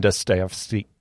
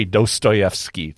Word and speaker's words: Dostoevsky: And then Dostoevsky, can't even say his name. Sorry, Dostoevsky.